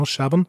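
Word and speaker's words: Chabon: Und ähm, das Spannendste an der Episode ist eigentlich, dass sie von äh, Michael Shabon, äh, also, oder ich glaube, so wird er ausgeschrieben, ausgesprochen, genau, Shabon, mhm.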